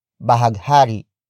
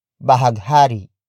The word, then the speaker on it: bahaghari: It is the object marker which introduces another noun that is not the focus of the sentence, and that is bahaghari. Bahaghari.